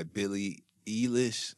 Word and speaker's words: Billie Eilish: I've been listening to this song called Ocean Eyes by Billie Eilish.